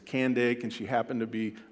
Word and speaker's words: Candace: It's not Candace. It's pronounced Candake, and that wasn't her name.